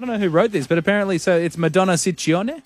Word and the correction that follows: Ciccone: Well, in Italian, it works the other way. So the double C's are hard C's and the singular C's are soft C's. – So Ciccone.